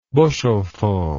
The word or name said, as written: bushelful